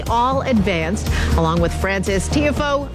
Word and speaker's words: Tiafoe: Tiafoe. – Tiafoe.